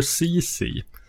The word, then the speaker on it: Ceci: Ceci Mm. Inte lika mycket household name. Tydligen uttalas namnet så. Jag fick tvungen att kolla upp det.